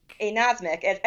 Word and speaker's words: anosmic: and not like anosmiac. – Anosmic. At least that's how I believe it's pronounced.